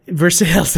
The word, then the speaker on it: Versailles: As the train stopped in a Versailles, I really never know how to pronounce the name of that town. – Versailles? – Versailles, that's the there one.